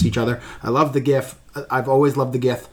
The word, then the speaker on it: Gith: Jith. – It is pronounced. – No, Gith. No, it is not pronounced Jith. – Yes, it is. – It is Gith.